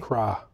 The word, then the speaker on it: Crha: Phil C-R-H-A. How would you pronounce that? C-R-H-A. – Cra. – Cra? You going Cra? Cra Maga. Mm-hmm. Tyler Rosewood, Anthony Guirgis. – Spell it. – G-U-I-R-G-U-I-S.